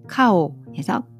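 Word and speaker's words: 카오 (靠): 음, 사성으로 돼 있고요. 그리고 발음은 카오에서 카오 이렇게 읽습니다.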